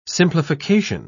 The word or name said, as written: simplification